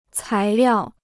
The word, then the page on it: 材料: cái liào